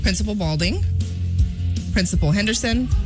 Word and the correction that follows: Belding: I might have mispronounced the first one. Let me try that again. Principal Belding, Principal Henderson, Principal Miller, Principal Morris.